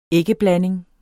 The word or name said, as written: æggeblanding